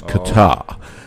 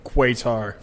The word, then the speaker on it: Qatar: Oh, so when the British had it, they pronounced it correctly as Qatar. Then they went on their own and switched it to Qatar.